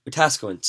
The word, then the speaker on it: Wetaskiwin: Am I pronouncing that right? – Wetaskiwin, sorry.